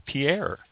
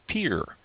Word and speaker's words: Pierre: One of the things we learned when we were talking to South Dakota this past year is that the name of the capital of South Dakota is not Pierre. And I have mispronounced it my entire life. It's Pierre. So all of you out there who are pronouncing it Pierre, it's actually pronounced Pierre.